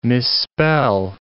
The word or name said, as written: misspell